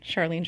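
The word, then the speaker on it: Sharleen: My Instagram is at Charlene Joint.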